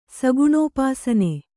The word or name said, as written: ಸಗುಣೋಪಾಸನೆ